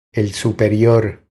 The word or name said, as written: El superior